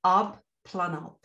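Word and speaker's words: Abplanalp: It's Up Planalp. So it's A-B-P-L-A-N-A-L-P.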